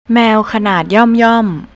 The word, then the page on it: maew: thai